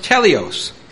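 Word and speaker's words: teleos (τέλειος): And it's pronounced teleos. Teleos.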